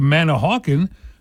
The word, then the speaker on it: Manahawkin: And like I said, if you go to Manahawkin, the emphasis is on a different syllable in Manahawkin as it syllable. – Is in Manalapan.